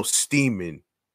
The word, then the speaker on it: Steeman: This, I recently heard a couple weeks ago in regards to Thomas Seaman. No, Steeman. That rhymes with Seaman, which I didn't even know about this right there.